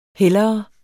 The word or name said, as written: hellere